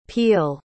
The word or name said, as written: Peele